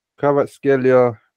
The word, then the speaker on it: Kvaratskhelia: Kvaratskhelia, no? – That's how you say it.